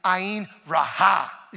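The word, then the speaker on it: ayin raha (עין רעה): The way you say bad eye is ayin raha. And you got to put a little emphasis on the end right there, okay?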